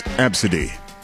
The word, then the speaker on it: ABCDE: The name A-B-C-D-E, pronounced Absidy, as a first name, Absidy.